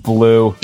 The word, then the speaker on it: BLU: The BLU Scout. – I believe that's pronounced blue.